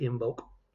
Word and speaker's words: Imbolc: But yeah, so Imbolc. I-M-B-O-L-C is how it's spelled, but apparently you say it with a G, but I'm going to say Imbolc.